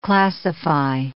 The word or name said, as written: classify